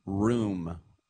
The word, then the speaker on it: Room: Think about the goalie, Aloy Room, R-O-O-M, just as you would spell it, yes.